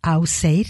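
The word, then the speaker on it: Ásgeir: Auseir se pronunciaría porque es un grupo encabezado por Trausti de Islandia.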